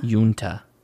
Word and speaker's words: Uinta: Unta.